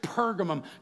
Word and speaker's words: Pergamum: In fact, the closing, the last words of the letter to the church at Pergamum. Just turn to the person next to you and say, Pergamum. If you're not spitting, you're not saying it right.